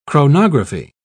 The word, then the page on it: chronography: British English